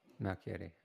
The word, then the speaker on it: Malchiodi: Malchiodi.